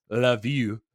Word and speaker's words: Lviv: And this is that place now is what we would know as this one's spelled LVIV, which is actually pronounced Love You.